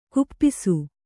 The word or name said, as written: ಖಪ್ಪಸು